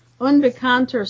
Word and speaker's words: unbekannte: Stress on the first syllable. Der Unbekannte.